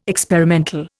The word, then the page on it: experimental: Indian